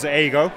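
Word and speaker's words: A-go (英語): It's kind of unusual to anybody who isn't familiar with Japan, but the number 5 in Japanese is pronounced go. So A5 is A-go. And A-go in Japanese means English learning or English language.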